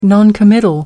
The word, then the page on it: noncommittal: American English